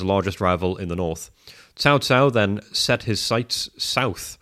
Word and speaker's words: Cao Cao: Yes, pronunciation looks like cow cow, but the C makes a TS sound. So Cao Cao was consolidating power in northern China.